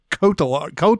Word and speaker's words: Cotillard: It's Marion Cotillard. Cotillard. Yeah. – Marion Cotillard. – Yeah. You have to like take those L's and just not pronounce them for whatever reason.